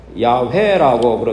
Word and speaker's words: 야훼: 하나님 이름이 J-H-W-H 그래서 자음으로 되어서 발음하기 힘들기 때문에 이 J-H-W-H 하나님의 이름을 사용하지 않고 아도나이라고 불렀습니다. 그래서 나중에는 모음을 넣어서 A와 E를 넣어서 JHWH에서 IE를 넣어가지고 여호와를 야훼라고 그렇게 발음하게 된 것입니다.